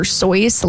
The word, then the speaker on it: Seuss: Dr. Seuss is actually supposed to be pronounced like Dr. Soyce, like moisture.